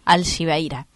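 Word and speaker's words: Alxibeira: Y la X se pronuncia como SH. Sos un genio. Alcibeira, ¿puede ser? Alcibeira es la pronunciación.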